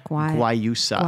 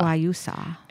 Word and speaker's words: Guayusa: Guayusa. – Guayusa.